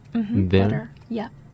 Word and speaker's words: there: There. Yeah.